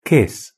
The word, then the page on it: kiss: British English